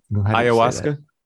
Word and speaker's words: ayahuasca: How do you say that? Echinacea? – Ayahuasca? – Is that it? – Yeah.